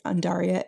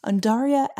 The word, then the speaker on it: Undaria: And, you know, I for a while was pronouncing it Undaria like an idiot. So if you, like me, cannot read, I've just helped you sound smart because it's actually Undaria, Undaria algae body oil.